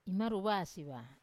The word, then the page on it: imaruwasi va: wamesa